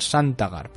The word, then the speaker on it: Santagar: Sasgar que era el, creo que lo he dicho bien, es que tiene un nombre un poco, Aaron Santagar, perdón, era el, herrero eh, de, la, de la Fortaleza Roja.